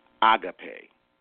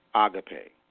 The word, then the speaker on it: agape: Now, the correct pronunciation in the Greek is agape. Agape. That's the correct pronunciation of agape.